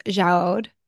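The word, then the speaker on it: Jaouad: I think her last name's pronounced Jaoud.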